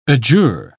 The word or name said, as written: adjure